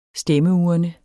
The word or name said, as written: stemmeurne